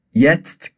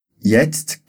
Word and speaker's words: jetzt: Lembra que na pronúncia de jetzt tem esse som de tst, que é como se a gente falasse TST tudo junto, tá ok?